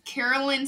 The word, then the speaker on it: Kerrelyn: It is Carolyn Sparks. But it's spelled K-E-R-R-E-L-Y-N.